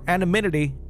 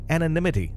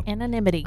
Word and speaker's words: anonymity: For anonymity, or I can never say this word, did I say it correctly? Anonymity. There we go. – Anonymity. – I added an M where it shouldn't be. Anonymity.